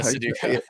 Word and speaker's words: Sudoku: Was it Sudoku? – Yeah. Sudoku.